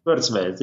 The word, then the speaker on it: Kurzweil: Ray Kurzweil? – Kurzweil, yeah.